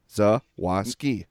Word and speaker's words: Zawaski: My last name's the same way. People see it, and it's like a lot of letters, and they get scared. It looks just like it sounds. Zawoski.